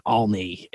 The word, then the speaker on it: Olney: It's 20th and Olney. It's O-L-N-E-Y.